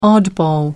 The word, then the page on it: oddball: American English